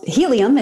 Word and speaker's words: Healium: So Helium, it's spelled H-E-A-L-I-U-M, like healing.